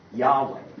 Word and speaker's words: Yahweh: because if you say it correctly, it feels like breathing, where you breathe in on Yahweh.